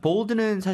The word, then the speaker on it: bold: 근데 영어로 발음하실 때는 이름절로 하셔야 돼요. 볼드가 아니고 bold.